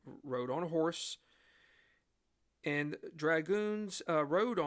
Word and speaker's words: dragoon: Colonel Banastre Tarleton's 180 Loyalist soldiers were also known as dragoons. You heard what I said, dragoons, right? Not dragons, but dragoons. Dragoon is spelled D-R-A-G-O-O-N.